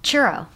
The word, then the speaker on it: Churro: You say Churro. Churro. – Churro. – Like those fried sticks? – Yeah, yeah.